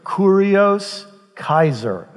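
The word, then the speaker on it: Kurios Kaiser (κύριος Καῖσαρ): Kurios Kaiser is how you say it in Greek.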